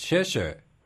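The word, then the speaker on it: sheshe (谢谢): So even quite differently, like thank you in Cantonese is doche, and in Mandarin it's sheshe, written the same.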